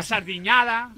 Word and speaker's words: sardiñada: Claro, la sardiñada. Lo has pronunciado muy bien.